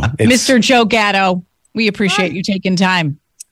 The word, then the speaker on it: Gatto: We feel so close to him because he pronounces his name like a true Rochesterian would. Gatto.